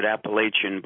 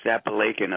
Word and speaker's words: Apalachin: Well, in a town in upstate New York called Apalachin, it looks like you want to pronounce it Appalachian, but it's pronounced Apalachin up there, lived a mafia boss named Joe Barbera.